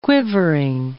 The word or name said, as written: quivering